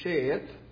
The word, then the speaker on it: Sheth: His name is pronounced in the Hebrew Sheth, and it means appointed one, and that's why Eve named him. You notice there in the text, Sheth, his name was, and she said, because God hath appointed him in the place of Abel, whom Cain killed.